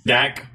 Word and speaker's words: Dak: Dax Prescott has. Or I'm sorry, Dak. How do you say it? Dax Prescott. – Dak.